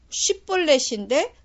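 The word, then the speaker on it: 쉬뻘렛인데 (שיבולת): 그래서 한국 성경대로 얘기를 하자면은 쉬뻘렛인데 그 사람들은 쉬를 못하고 시라고 얘기를 한 거예요.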